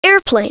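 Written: airplane

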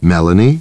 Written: Melanie

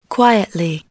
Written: quietly

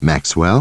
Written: Maxwell

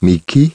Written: Mickey

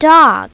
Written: dog

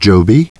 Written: Joby

